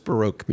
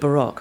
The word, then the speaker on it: Baroque: And just for my benefit, but maybe a few of our listeners, what is Baroque music? – Well, we call it Baroque, of course. – Oh, and, I'm sorry. Forgive my American pronunciation.